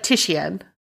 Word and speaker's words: Titian: It's either Titian or, like, Titian. – Hold on. – You would pronounce the I. It's Italian. – Artist pronunciation. Wow, we are both wrong. – Oh, it's Titian.